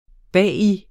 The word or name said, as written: bag